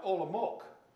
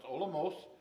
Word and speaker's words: Olomouc: Now, I was always pronouncing to myself this town, Olomouc, but the C is, in their language, is an S, more like an S sound, so it's Olomouc, Czech Republic.